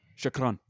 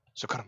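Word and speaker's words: Shukran (شكرًا): How do you say it? Shukran. Shukran.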